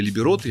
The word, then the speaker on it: либерота: То есть ни с того ни с сего в общении с э, нашим полпредом он зачем-то назвал город центром мерзотной либероты. Я правильно ударение ставлю? Угу.